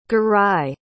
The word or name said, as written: garai